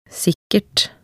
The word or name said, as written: sikkert